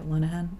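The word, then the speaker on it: Lenehan: God, I hope I'm saying that right. It's L-E-N-E-H-A-N. – Sounds like it. Linehan.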